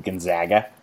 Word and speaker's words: Gonzaga: But the thing with Gonzaga, and there are so many people like situated in Eastern Washington that are spitting mad that we're not calling them Gonzaga. So many people. Because that's how you're apparently supposed to pronounce it.